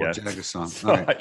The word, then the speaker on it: Jagusaw: It is Jagusaw, which I've been calling him Jagusa for about 6 months. – But, yeah, so the emphasis is on the last syllable, Jagusaw.